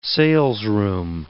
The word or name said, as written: salesroom